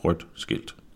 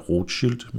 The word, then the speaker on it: rødt skilt: Rødt skilt, rødt skilt. Derfor burde den korrekte udtale også være rødt skilt, men der er også mange, der kalder dem rødt skilt. Det må du sådan set selv om.